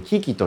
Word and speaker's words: QUI: からのですね、え昨日は、え僕の仲間ですね、キキという、 QUI、 QUI と書いてキキ